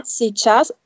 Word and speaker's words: сейчас: Hier schreibt man zum Beispiel Sichas, ja, Sichas. Mit diesem I, beziehungsweise diesem umgedrehten m, äh, n, n mhm. – und obendrauf dieses umgedrehte Dach. Ja. ja, сейчас, aber man spricht es nicht aus. – Was spricht man nicht aus? – Dieses i, I.